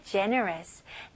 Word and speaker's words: generous: 그 다음에 generous. 네, generous는 너그러운 그리고 관대한이라는 뜻을 가지고 있어요. 네, 발음 연습해 볼게요. G로 시작했지만 발음은 J, J, J 발음입니다.